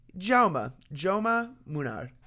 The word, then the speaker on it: Jaume Munar: How do you pronounce his first name? I'm gonna go ahead and give a little ATP listen. Jaume Munar. So that's the ATP pronunciation on their website.